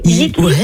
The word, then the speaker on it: YiQi: Yiki, Yiki ça veut dire ensemble en chinois.